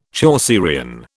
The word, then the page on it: Chaucerian: British English